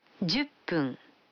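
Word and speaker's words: Дзюппун (十分): А 10 минут – джуппун. Вы заметили, что когда фун добавляется к 10, дю, то произносится пун. – Послушайте и повторите каждую фразу. 5 минут. – Гофун. – 10 минут. – Дзюппун.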